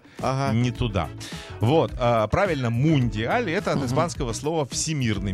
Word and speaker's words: мундиаль: Во-первых, мне стало интересно, как правильно говорить, мандиаль или мундиаль. Оказывается, правильно, мундиаль. Угу. Вот, что лично мне режет ухо.